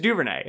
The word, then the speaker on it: Duvernay: It's not like Lefleur. It's just Duvernay. It's not like Duvernay.